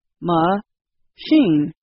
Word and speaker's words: machine: For example, the word machine has two parts, ma-shin.